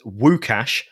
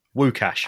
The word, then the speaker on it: Łukasz: And the correct pronunciation is Łukasz Fabianski. – No. – Yeah, Łukasz. – Łukasz. – His first name. Łukasz. – How about W-O-O and then Cash. Łukasz.